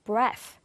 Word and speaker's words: breath: 그 이유는 뭐냐면 여기 앞에 나와 있는 이 단어의 TH는요. 무성음이라고 해서 breath, breath라고 발음이 되고요.